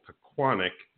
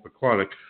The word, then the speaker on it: Pequannock: For those of you who wonder how to say that town's name, it's called Pequannock. It's a lot of people don't know how to say it. It's actually called Pequannock.